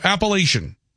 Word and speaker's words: Appalachian: Text writes, coming from somebody who grew up in the Appalachian Mountains, you are saying it correctly. So Appalachian, that's how it's said.